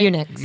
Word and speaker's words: eunuchs: Nullos are not necessarily transgender, but identify as Enochs. How do you say it? Am I saying it right? – Eunuchs. – Eunuchs.